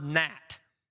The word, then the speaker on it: gnat: How do I know how to correctly spell the word gnat? That pesky little silent G in front.